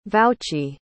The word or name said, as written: vouchee